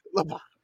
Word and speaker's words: Lubbock: Yeah, it rhymes with Hamhock. – Hamhock. Lubbock. Lubbock. – Lubbock. – Like Shiner Bach.